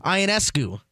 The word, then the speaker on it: Ionescu: An NBA versus WNBA three-point contest with Steph Curry versus Sabrina Ionescu.